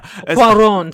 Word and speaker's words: Cuarón: Alfonso Cuarón. – Yeah. – Cuarón, excuse me.